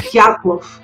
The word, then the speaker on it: Dyatlov: How do you say that? Dyatlov? Is that how you say that? – It's okay. Dyatlov.